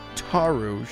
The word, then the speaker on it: Taru: Well, yeah, we have some people to thank, and I'm going to go first because Taru, I think it's Finnish, and I think that the rule in Finland is that you put the emphasis on the first syllable. So I'm going to say Taru.